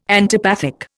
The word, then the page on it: antipathic: Indian